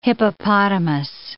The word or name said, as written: hippopotamus